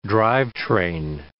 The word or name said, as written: drivetrain